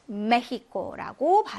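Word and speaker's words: México: 얘는 메히코라고 발음이 됩니다. 그래서 요거는 네, 강한 히읗 발음이 돼서 역시 발음이 다릅니다.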